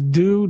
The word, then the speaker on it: Dew: Yeah, the first name is Do, D-E-W, and the last name is spelled R-I-T-E, Do Right.